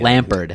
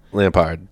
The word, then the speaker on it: Lampard: So the English team, it might have been whatever, but Frank Lampard, am I saying his name right? – It's a D at the Lampard. – End. Lampard. – Lampard.